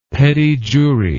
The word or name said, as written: petit jury